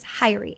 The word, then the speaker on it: Hyrie: Almost sounds like Kyrie, like Kyrie Zane, but it's Hyrie, H-Y-R-I-E. – H-Y-R-I-E. Okay. – Yeah. – And the middle name was Von. – Von Rotunda. – V-O-N? – V-O-N.